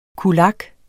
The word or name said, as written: kulak